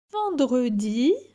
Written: Vendredi

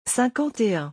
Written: 51

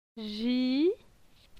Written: Ji

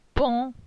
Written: Pon

